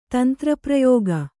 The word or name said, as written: ತಂತ್ರಪ್ರಯೋಗ